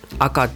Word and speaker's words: AKA: アルファベットで AKA と書いて赤っていう読む